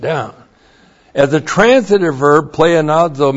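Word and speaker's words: pleonazo (πλεονάζω): H-I-N-A. That introduces the purpose clause. Hina plus the subjunctive of the verb pleonazo. That's P-L-E-O-N-A-Z, long O, pleonazo.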